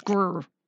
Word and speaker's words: Greer: It's Greer. – Yeah, grr. – You gotta pronounce it right.